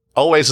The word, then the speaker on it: Alvvays: That's Always is spelled A-L-V-V-A-Y-S, like the Vitch. So if you're looking them up, type it like that.